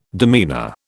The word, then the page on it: demeanour: British English